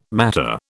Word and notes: matter: British English

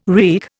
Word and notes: wreak: Indian

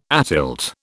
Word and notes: atilt: British English

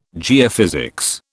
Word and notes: geophysics: British English

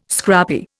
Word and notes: scrappy: Indian

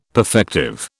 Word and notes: perfective: British English

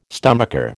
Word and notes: stomacher: American English

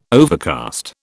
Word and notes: overcast: British English

